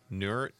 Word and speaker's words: Nauert: How do you say that name? Nauert? – Nauert. – Nauert. N-A-U-E-R-T.